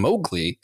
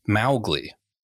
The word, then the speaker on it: Mowgli: In fact, Larry Clement's daughter apparently got really angry at the film specifically because they kept saying Mowgli and it should be pronounced Mowgli. So technically the correct pronunciation is not the one in the film.